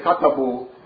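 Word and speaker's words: Katabu (كتبوا): They are written but not pronounced, but they are very, very rare in the Arabic language. Like you say, Katabu, yeah. – They wrote the Aleph, you don't pronounce it, but we don't know why it's there.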